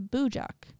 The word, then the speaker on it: Bujok: Bujok? It's B-U-J-O-K.